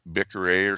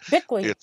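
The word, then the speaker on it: Biklé: And then there's also Anne, and I can't get her last name right because it's pronounced Bickery or something like that. – Bickley.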